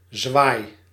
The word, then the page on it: flourish: American English; [ˈflɝ.ɪʃ]